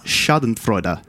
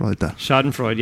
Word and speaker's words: Schadenfreude: It's pronounced Schadenfreude. It's German. – Schadenfreude, yeah, huh? – Schadenfreude.